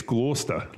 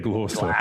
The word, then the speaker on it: Gloucester: You know, you're not saying it right. It's Gloucester. Like, they got to. Yeah, Gloucester.